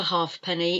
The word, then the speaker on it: halfpenny: It's not a halfpenny. In old money, we used to have a halfpenny, a halfpenny, halfpenny, halfpenny. You don't pronounce the F.